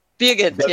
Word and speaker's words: Birgit: Birgit. Yeah, it's like beer and git.